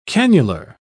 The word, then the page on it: cannular: British English